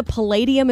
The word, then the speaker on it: Palladium: Is it the Palladium? Am I saying that correctly in Los Angeles? – Yes.